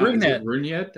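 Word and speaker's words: Rougned: First of all, I want to ask you, how do you pronounce his first name? Because I know it's Odor, but I do not. – Runyet? – Okay. That's what I thought it was, but I couldn't remember how to say it.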